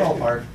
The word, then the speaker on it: Svalbard: You know how to pronounce it? Did I get close? It begins with an SV, right? – Svalbard.